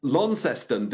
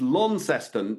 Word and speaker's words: Launceston: I mean, I was told when I was in Launceston that you've got to hit it's Launceston, not Launceston. Is that correct? – Lawn, yeah, got to say lawn. There's no lawn there, it's Launceston.